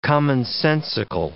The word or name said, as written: commonsensical